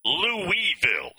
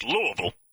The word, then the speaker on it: Louisville: You can tell Griff is a carpetbagger by the way he pronounces Louisville. Is that what he says? It's Louisville. If you're a member, if you live there, if that's your home, it's Louisville. It's not Louisville.